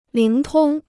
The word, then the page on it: 灵通: líng tōng